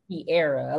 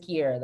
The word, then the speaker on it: Kearra: Kearra or Kearra? – Kearra. A lot of people get perfect. That confused. It's Kearra though.